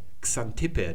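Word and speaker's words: Xanthippe: Wird T behaucht ausgesprochen, Xant. Und hier hinten dann Ippe, Xantippe. Das ist die Frau von Sokrates, die mir sprichwörtlich in die Sprachen der Welt eingegangen ist als garstige Ehefrau.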